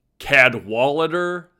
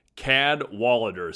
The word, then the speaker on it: Cadwallader: The center was started in its original form by Cadwallader C. Washburn. I think that's the pronunciation, but just a top shelf name. Cadwallader, C-A-D-W-A-L-L-A-D-E-R.